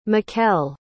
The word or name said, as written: mekelle